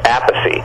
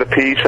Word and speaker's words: Appice: And then everybody kept pronouncing it Appice. And then when he hooked up with Rod Stewart, Rod used to call him Appice. So he stuck with that.